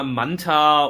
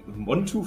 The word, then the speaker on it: Montu: Äh, ich habe den Namen endlich richtig ausgesprochen. Montu ist ein cooler Invert. Ähm, hey, was hast du denn sonst gesagt? – ich habe ich hab immer Manta oder also,